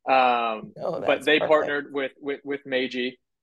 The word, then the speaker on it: Meiji: I don't know if it's Meiji, Meiji, but yeah, it's Meiji.